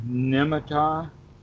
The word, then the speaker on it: nimitta: You're mentioning the word nimitta. – Ah, that's how you pronounce it? Nimitta.